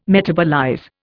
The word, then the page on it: metabolize: Indian